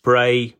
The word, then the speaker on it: Brea: For anyone that doesn't know, Bray is spelled B-R-E-A in this case, and that is the Cornish word for hill.